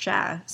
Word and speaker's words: chat: Yeah, it's pronounced chat, C H A T, so it looks like chat.